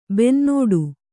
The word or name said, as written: ಬೆನ್ನೋಡು